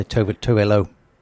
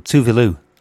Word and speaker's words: Tuvalu: Now, how do you say Tuvalu? You say Tuvalu. – Oh, Tuvalu.